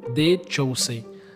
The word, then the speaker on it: The Chosen: The Chosen.